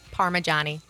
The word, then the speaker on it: Parmigiani: Do I pronounce that right? – No. – I didn't think so. Go ahead. – Maria. – Parmigiani. – Parmigiani. – Yeah, so Parma, like the region in Italy, and then Johnny, like a guy's name.